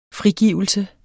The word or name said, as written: frigivelse